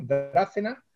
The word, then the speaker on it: Drácena: La editorial eh, es, que me perdonen porque nunca sé bien cómo se pronuncia, es Drácena, creo, ¿no? Drácena que lleva tilde en la A, Drácena.